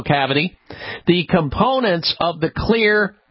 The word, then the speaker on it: Xlear: Now, you can find Clear, X-L-E-A-R, that's how you spell it.